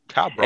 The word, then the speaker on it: tableau: How do you pronounce it? It's a weird T. T-A-B-L-E-A-U. – Oh, tableau.